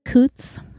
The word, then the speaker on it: Coots: Elizabeth Coots, rhymes with boots.